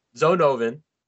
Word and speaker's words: Zonovan: Zonovan. – I think that's how I pronounced it. – I think you're right. That's how you pronounce it, dude.